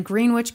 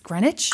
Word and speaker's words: Greenwich: Which, W-I-S, it's Greenwich? – I think it's pronounced Greenwich.